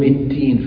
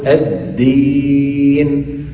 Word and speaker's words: الدين: في الفاتحة بالذات بعض إخواننا لما في كلمة مالك يوم الدين يأتون بها شبيهة بالتاء. مالك يوم الدين يوم التين فيأتون بها مهموسة وقريبة من التاء. ما يصح. التين شيء والدين شيء آخر. إذن علينا أن ننطق الدال دالا فنقول الدين سواء في كلمة الدين أو في غيرها من الكلمات التي حوت هذا الحرف وهو حرف سهل كما ترى.